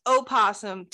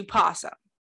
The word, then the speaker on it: opossum: So okay, technically, basically everybody, especially in the South, shortens opossum to possum. Like, okay. We just don't pronounce the O.